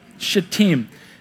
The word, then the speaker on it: Shittim: Then Joshua, there's our guy now, Joshua, son of Nun, secretly sent two spies from Shittim, is how you pronounce that. Yep, Shittim.